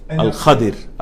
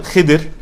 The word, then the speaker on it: Al-Khadir: The story of Musa and Al-Khadir. Al-Khadir. Al-Khadir. Now. There's two different ways to pronounce it. Khidr. Al-Khadir is more correct.